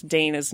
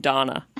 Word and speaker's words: Dana: Dana's name properly. He never learned how to say Dana. He would call him Donna.